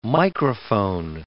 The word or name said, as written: microphone